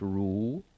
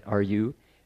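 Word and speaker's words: rú: So the first character is pronounced Ru, right? R-U, and that mark on top shows that it's a rising tone. So it's Ru. Then Mo is second, it's a rising tone, right?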